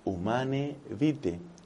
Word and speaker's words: Humanae Vitae: El Papa Pablo VI lo dijo muy claramente en su encíclica tan discutida pero tan profética, Humanae Vitae se pronuncia y se escribe Humanae Vitae, pero es latín y la manera de pronunciarlo es Humanae Vitae.